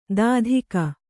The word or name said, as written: ದಾಧಿಕ